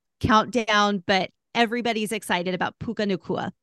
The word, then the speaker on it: Puka Nacua: Puka Nacua. – Puka Nacua making waves on the NFL gridiron.